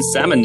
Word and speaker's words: salmon: Yeah, I'm just going to start pronouncing the L in salmon now.